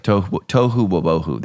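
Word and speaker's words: tohu wobohu (תֹהוּ וָבֹהוּ): I'm not pronouncing it right. We'll come back next week with the correct pronounce. It's like tohu wobohu. There we go. – That's right. – That's the Hebrew. Tohu wobohu. So that's how you say it.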